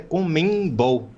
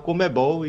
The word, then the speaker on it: CONMEBOL: E só, só ressaltar, eu nunca tinha pensado que a pronúncia correta é Comembol, né? Se falava Comebol e, enfim.